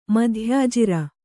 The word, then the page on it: ಮಧ್ಯಾಜಿರ: kannada